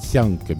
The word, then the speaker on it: tchank: Alors, on écrit tchank, T-C-H, mais il faut prononcer tianque, bien sûr, hein, comme T-I-A-N, comme à la tienne, Étienne.